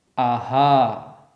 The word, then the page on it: aḥ: sanskrit